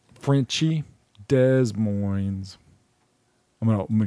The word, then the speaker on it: Des Moines: Duh, moin, moin. Rhymes with loin. Des Moines.